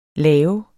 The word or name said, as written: lave